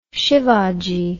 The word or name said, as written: Shivaji